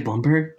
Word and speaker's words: Blumberg: You know, did I say Bloomberg? – It's it's actually both. We spell it with a U, so as not to be confused with you know the New York guy.